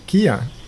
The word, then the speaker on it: Keeyon: I think probably spelled like K-I-A-N. And so he was like, that's a cool name. And then he named me Keeyon. – Cool. – Spelled K-E-E-Y-O-N.